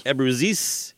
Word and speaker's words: Abruzzese: My birthday, 1993, through Epic Records, produced by Brendan O'Brien, and it's the first album to feature, I don't know, how do you say the drummer's name, Dave Abruzzese? – Abruzzese, which I believe by this time was their 17th drummer.